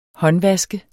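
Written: håndvask

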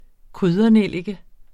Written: kryddernellike

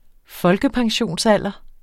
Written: folkepensionsalder

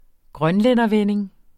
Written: grønlændervending

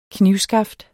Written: knivskaft

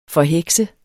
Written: forheksede

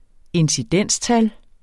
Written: incidenstal

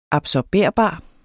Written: absorberbar

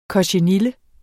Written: kochenille

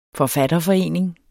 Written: forfatterforening

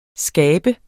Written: skabe

